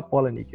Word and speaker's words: Palahniuk: O, se escreve Palahniuk, mas se fala Polanic.